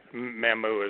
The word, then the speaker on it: Mamou: Or Mamou is actually Mamou is how it's pronounced.